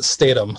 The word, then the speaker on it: Statham: So the actor Jason Statham, he pronounces the TH together. We pronounce it Statham. So the silence on the H.